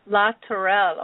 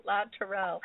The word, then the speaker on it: La Tourelle: And Maggie, I'm going to have you pronounce your last name because I'm not sure I was pronouncing it correctly. – It's La Tourelle. – La Tourelle. Oh, yes, I was not pronouncing it as fancy. It sounds much more beautiful the way you say it, La Tourelle. So that is Maggie, M-A-G-G-I-E, and La Tourelle is L-A-T-O-U-R-E-L-L-E, and both of those are .coms, The Gift of Alzheimer's, and Maggie La Tourelle.